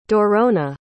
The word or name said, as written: Dorona